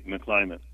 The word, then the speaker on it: McClyman: Like climbing a tree, McClyman.